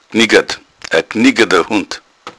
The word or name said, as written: gniggadd